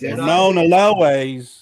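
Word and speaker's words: Ramón Allones: And then the same with the Ramon Alones, because I've been hearing people pronounce the L as a Y, Ramon Alones.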